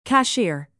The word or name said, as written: cashier